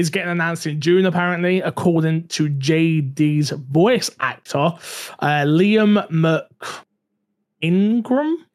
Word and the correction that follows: McIntyre: I said that right? – McIntyre. – How do I say that so wrong? McIntyre? Merck? Yeah, I look at Mick. – I think that's how it's pronounced, but I could McIntyre, be I think you're right.